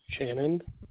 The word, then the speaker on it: Shannon: Shannon Sullivan. – Would you spell that first name for me, please? – S-H-A-N-N-O-N.